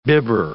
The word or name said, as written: bibber